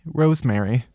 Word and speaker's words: Rosemarie: Rosemary. It's spelled Rose, but then Mary like M-A-R-I-E. Rosemarie.